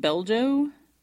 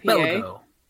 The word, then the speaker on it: Belgo: I'm going to go with Beljo. Because it's a Belgian style IPA. – Belgo. – Yeah, it's either Belgo or Beljo. It's probably Belgo.